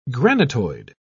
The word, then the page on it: granitoid: British English